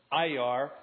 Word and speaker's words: Iyar: 17th day of the Hebrew month Iyar. That's how I pronounce it.